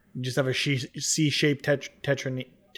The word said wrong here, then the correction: tetromino: I can never pronounce it right. – Tetromino.